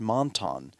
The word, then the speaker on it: Monton: Brad Monton rhymes with wonton soup, if you want to remember that.